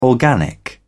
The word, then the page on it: organic: British English